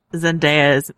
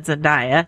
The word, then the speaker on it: Zendaya: Because first of all, it taught me that I was pronouncing this woman's name wrong. And I might still be pronouncing wrong, but I'm closer than I used to be when I said Zendaya. It's not Zendaya.